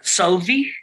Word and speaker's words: Solveig: Solvi, I believe, is the pronunciation, Solvi, but yeah. maybe we'll have a Nordic person correct us. But I believe it's Solveig.